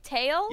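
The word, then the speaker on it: Tael: Tail? – Yes.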